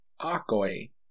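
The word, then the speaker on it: akoe (ἀκοή): Now, hearing in Greek here, it's pronounced akoe, A-K-O-E, akoe.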